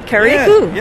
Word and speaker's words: Carriacou: I said it right? – You say it right, Carriacou.